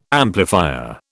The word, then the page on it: amplifier: British English